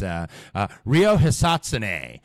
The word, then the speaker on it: Rio Hisatsune: Rio Hisatsune.